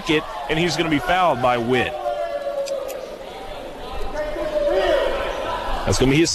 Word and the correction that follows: Witte: Accent on the E at the end of his last name, W I T T E. Witty.